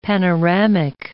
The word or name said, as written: panoramic